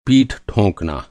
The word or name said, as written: ठो